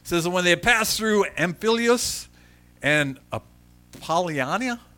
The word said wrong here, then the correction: Apollonia: did I say that right? No, I didn't even come close to it. Apollonia? Excellent, Apollonia. My pronunciation is very bad.